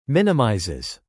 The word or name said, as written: minimizes